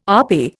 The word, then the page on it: RP: Indian